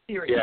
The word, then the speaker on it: Sirius: How's that pronounced? Sirius? – Well, thank you. Sirius.